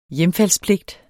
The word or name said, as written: hjemfaldspligt